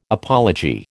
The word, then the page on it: apology: American English